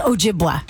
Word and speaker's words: Ojibwa: We learned how to say the Native American tribe Ojibwa today because I was calling it Ojibwe or something like that. And it's Ojibwa, just in case you ever need to know that on Jeopardy, you will now win.